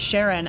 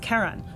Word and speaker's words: Charon: So the community pronounces it Charon after Charlene, but it's officially named after a mythological figure. So and the correct way to pronounce it in that case is Charon, but in a scientific conference you're not going to hear that pronunciation.